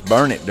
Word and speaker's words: Burnet: It's burn it, darn it. Can't you learn it? – Oh, so, burn it.